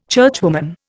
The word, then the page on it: churchwoman: Indian